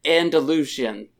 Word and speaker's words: Andalusian: Andalusian. And I'm pretty sure I said that right. – Yes. – Mm-hmm. – You did.